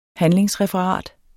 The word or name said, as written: handlingsreferat